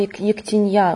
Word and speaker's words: ектенья: В слове «ектенья» ударение на последний слог падает. – Да, в служебнике оно стоит прямо очень так уверенно. Более того, там стоит тяжелое ударение, которое означает вот так и никак иначе.